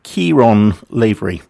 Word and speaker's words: Ciarán Lavery: Right, next is, and I have to pronounce this right, Ciarán Lavery.